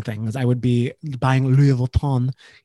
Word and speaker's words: Louis Vuitton: He ran up the room and food bill of almost $3,500 plus $7,500 in limo charges and fraudulent Louis Vuitton luggage purchases. I like how I can pronounce Louis Vuitton. I don't know why you said it like that, but correct.